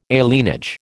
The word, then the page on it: alienage: American English